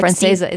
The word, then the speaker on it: Francesa: but others will know is Mike Francesca, the big sports. – Francesa, isn't it Francesa?